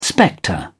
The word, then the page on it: spectre: British English